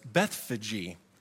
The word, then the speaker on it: Bethphage: As they approached Jerusalem and came to, funny enough, this is actually pronounced Bethphage. I've been saying that wrong for many, many years.